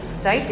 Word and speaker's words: Stuyt: Is that how we say it? How should we say it? – Yeah, we call it Stite dairy cheese because we like to shorten it, but I guess in theory it would be called Stite Dairy Farmstead Cheese Company.